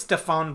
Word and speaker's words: Stéphane: Another comic both me and Dad read recently is Toys in the Basement by Stephanie Blanquet. I'm sorry if I said that wrong. – I think it's Stéphane Blanquet. – Okay, I said that wrong. Yeah, it's French.